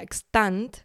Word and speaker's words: Xtant: Luego tenemos la feria Extant, eh, que se escribe X-T-A-N-T, pero bueno, todo esto estará en el PDF este que os podéis descargar.